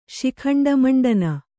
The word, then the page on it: ಶಿಖಂಡಮಂಡನ: Kannada